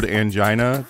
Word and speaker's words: angina: And also learning the word angina because it rhymes with vagina.